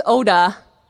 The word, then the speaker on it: Oda: It's Oda. Oh, Yoder! No, actually not Yoder. Oh, I know thousands of Yoders! No, it's Oda, as in Japanese for small rice field.